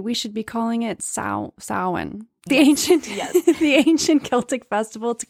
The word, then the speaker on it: Samhain: So yes, let's call it Samhain from now on. And the reason why I tripped up at first is because it's spelt Samhain.